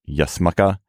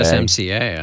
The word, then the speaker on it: USMCA: Yasmuka. – I don't think it's USMCA. USMCA. I think is the Charlie on the USMCA.